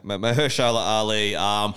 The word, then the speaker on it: Mahershala Ali: Mahershala, is that how you say it? Ali? – Yeah, Mahershala Ali.